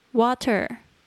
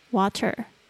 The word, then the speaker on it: water: 接下来我们讲饮料类 drinks juice J U I C E juice 果汁 milk M I L K milk 牛奶 water W A T E R water 也有人会念成 water，就是那个 t 的声音被浊化，美式的发音会有一个这样子的现象。